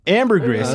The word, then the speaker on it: ambergris: here, Google is going to tell us how to pronounce it. – Ambergris.